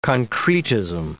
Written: concretism

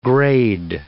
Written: grade

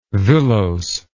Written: villose

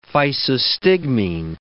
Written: physostigmine

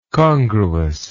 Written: congruousness

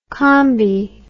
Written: combi

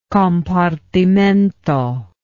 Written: compartimento

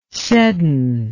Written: Seddon

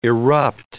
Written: erupt

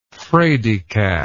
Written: fraidycat

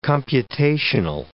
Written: computational

